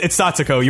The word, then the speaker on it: Satoko: But, and Sakuto and Rika. – Oh, I don't know if I got those right. – I think you said Sakuto, I think. You were close. It's Satoko.